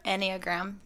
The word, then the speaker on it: Enneagram: Is that how you say it? Enneagram? – Enneagram, yeah. It means like nine.